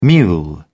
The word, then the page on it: mule: British English